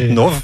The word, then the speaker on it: Pajitnov: Pajitnov.